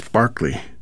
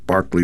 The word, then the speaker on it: Berkeley: The English composer, Sir Lennox Barclay, that's spelled like Berkeley, but Barclay like Barclay Square, born 1903, aristocrat on both sides of the family, although there's a question of some legitimacy on his pop's side.